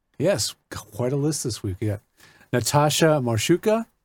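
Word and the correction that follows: Marchewka: Am I saying that right? – Marchevka.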